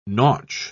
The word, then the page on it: nautch: British English